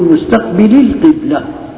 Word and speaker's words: مستقبلي القبلة: ولما بدنا ندرج فيها كمان ما بننطق بالياء لأن تحذف لالتقاء الساكنين، فنقول مستقبلي القبلة، هي شو أصلها؟